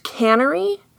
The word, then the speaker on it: Canary: Her birth name is Martha Canary. I've also seen it pronounced Cannery. – Uh-huh. – Or heard it pronounced, I guess.